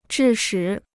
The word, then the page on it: 致使: zhì shǐ